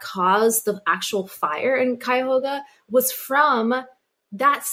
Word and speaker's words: Cuyahoga: Cuyahoga. – Cuyahoga, yeah.